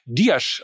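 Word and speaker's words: Dias: Er wechselt ebenfalls äh, zu Stuttgart und da er von Benfica kommt, bin ich mir relativ sicher, dass der 26-jährige Diasch ausgesprochen wird. Oder Lena Kassel? – Das äh, ist vollkommen korrekt, aber ich dachte, ich lass einfach mal laufen.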